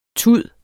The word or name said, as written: tud